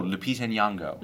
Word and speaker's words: Lupita Nyong'o: Oh, Lupita Nyong'o.